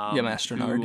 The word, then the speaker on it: Mastronardi: Mastronardi.